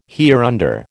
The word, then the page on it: hereunder: American English